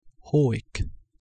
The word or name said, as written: huic